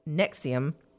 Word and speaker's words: NXIVM: NXIVM, pronounced Nexium.